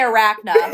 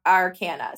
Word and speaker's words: arcana: Major arcana and then the minor arcana. – Okay, so major, yeah. I was going to say arachna. Arcana. Arcana? – Yeah. – Okay, major arcana and minor arcana.